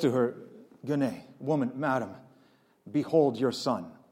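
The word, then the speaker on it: gune (γυνή): So the Greek word for woman is gune, or sometimes pronounced yune or junai. Let's just go with gune.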